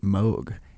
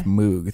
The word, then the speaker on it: Moog: I thought it was Moog the entire time for the last 30 years. – It looks like it's spelled Moog, but it is Moog. Yeah, like Vogue.